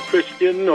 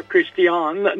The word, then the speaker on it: Christiane: All right, we're back now with Dr. Christian or Christiane Northrup. That's her pronunciation, actually.